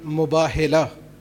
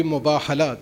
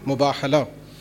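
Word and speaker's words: Mubahala (مباهلة): It's a ghalat al-am that sometimes is called Mubahila. It's on for the Hoza students, mashallah. You all know that it's on the wazn of Mufa'ala. So that would be Mubahala. The correct pronunciation would be Mubahala.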